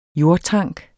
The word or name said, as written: jordtank